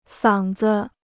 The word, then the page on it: 嗓子: sǎng zi